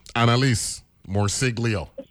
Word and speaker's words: Morciglio: Analise Morciglio. Am I pronouncing that name correctly? – Yes, you are.